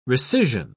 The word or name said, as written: rescission